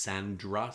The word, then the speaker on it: Sandara: I think it's pronounced Sandra. Sandra. It's S-A-N-D-A-R-A.